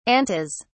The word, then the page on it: antas: British English